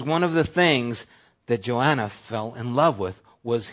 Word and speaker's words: Joanna: And how did he marry his wife, Joanna? He was in South Africa. They pronounce her name Jonah there.